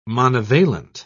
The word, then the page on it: monovalent: British English